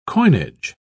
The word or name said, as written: coinage